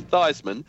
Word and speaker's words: Theismann: Former Washington QB Joe Theismann, or is it Theismann, officially changed the pronunciation of his name in college from Theismann to Theismann so it rhymes with Heisman.